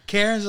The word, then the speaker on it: Cairns: Cairns, like how you pronounce it? – Cairns, okay. Yeah, I was not Karens, like the ones that are calling and doing something bad.